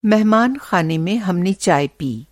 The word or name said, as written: मेहमान-ख़ाना